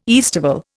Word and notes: aestival: Indian

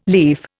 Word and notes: leaf: Indian

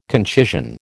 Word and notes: concision: American English

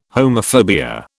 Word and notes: homophobia: British English

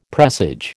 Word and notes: presage: American English

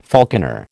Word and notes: falconer: American English

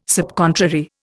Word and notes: subcontrary: Indian

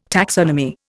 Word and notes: taxonomy: Indian

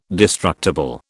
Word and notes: destructible: British English

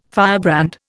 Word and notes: firebrand: Indian

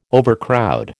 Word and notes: overcrowd: American English

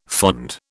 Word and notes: fond: British English

